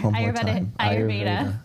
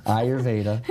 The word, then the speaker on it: Ayurveda: Ayurveda. – Ayurveda.